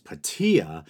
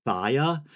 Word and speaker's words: Phthia: It's spelled P-H-T-H-I-A, and I heard the Latin pronunciation pronounced as Patea, and I think the Greek pronunciation, which we'd be more interested in, is Thia. I was almost tempted to say Pythia, but that's the Oracle of Delphi. But let's go with Thia. "So,